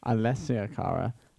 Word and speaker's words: Alessia Cara: So we have just listened to Logic featuring Alyssa Cara. Is that how you say it? – It's Alessia Cara and Khalid.